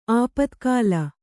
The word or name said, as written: ಆಪತ್ಕಾಲ